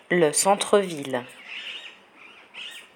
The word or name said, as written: le centre ville